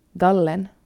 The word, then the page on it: Gallen: utan accent; finlandssvenska